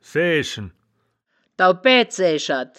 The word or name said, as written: saeaeschen